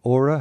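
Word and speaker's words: Åre: Yeah, Ora is the best skiing area in Sweden.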